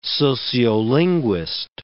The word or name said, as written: sociolinguist